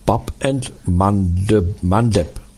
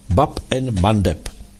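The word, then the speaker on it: Bab el-Mandeb: Oni, že jo, zastavili dopravu i v tom, v té bráně nářků, a vyslovuje se to Bab and Mandeb. Bab and Mandeb.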